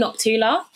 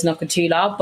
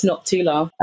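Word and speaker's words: Nokuthula: Everyone says Nokutula, but the U is actually silent, so it's Noctula.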